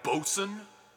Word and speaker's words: boatswain: Then you have the bosun. – The bosun. – Boatswain. – It's not spelled as it's said. – No, it's spelled like boatswain, right? – Yes, indeed.